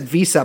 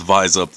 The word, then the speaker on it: VISUP: I always said Visa, but I also said it out loud to anybody. – Well, it's not a real word. So, I mean, there's no real formal pronunciation anyway. But I mean, I always went with Vise Up, though.